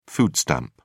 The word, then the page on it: food stamp: British English